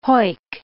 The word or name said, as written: hoick